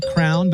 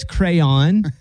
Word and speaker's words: crayon: You said crown, but it's crayon.